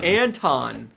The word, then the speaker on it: Anton: Anton? – 7 p.m. – Anton. Right? – We always say it wrong. – Two people corrected us in the last 24 hours. It rhymes with lawn and not...